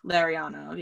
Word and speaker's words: Larionov: I put Igor Larionov. I hope that's how it's pronounced. – Larionov. – Larionov? – Larionov, yeah.